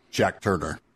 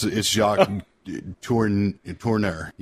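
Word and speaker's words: Jacques Tourneur: I prefer to call him Jack Turner. – Is that really how you say it? – No, it's Jacques Tournier.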